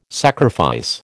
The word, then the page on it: sacrifice: American English